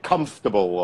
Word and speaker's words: comfortable: So it's like comfortable or vegetable, three syllables, you know.